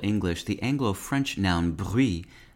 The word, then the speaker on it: bruit: The English noun brute is now considered archaic, apart from a medical sense that is pronounced like the French word bruit, and refers to one of the abnormal sounds heard on auscultation.